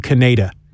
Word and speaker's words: Kaneda: But when they dubbed it to English for the English audience, they felt that having the main character named Kaneda, the American audience would find that silly, because they would think his name is Canada, which is our neighbor to the north. So they just changed the pronunciation to Kaneda.